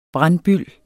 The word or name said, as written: BRANDBYLDEN